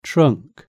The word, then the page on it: trunk: British English